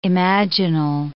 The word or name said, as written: imaginal